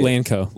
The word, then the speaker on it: LANCO: Lankon. – Lankon. – Like how? What is it? How do you say it? – Lanco. – Lanco? – Mm-hmm. – All right. So Lanco. – Lankon.